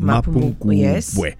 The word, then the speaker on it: Mapungubwe: is receiving the Order of Mapungubwe in am I saying it right? – Yes, the Order 100% of Mapungubwe.